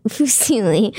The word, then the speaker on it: Fusilli: So the first one was Fusilini. What? – Fusilli, not Fusilini. Fusilli.